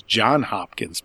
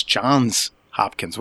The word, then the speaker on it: Johns Hopkins: That Johns has an S on it. No, most people are called John, so you figure it's John Hopkins, but it's Johns Hopkins.